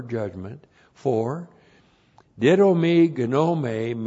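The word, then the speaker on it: gnome (γνώμη): That's gnome. So the key to didomi is knowing this noun. G-N long O-M long E. Gnome. You look at it and see gnome, but it's gnome.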